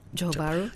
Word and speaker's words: Johor Bahru: Johor Bahru.